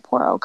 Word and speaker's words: Poro: Poro Company.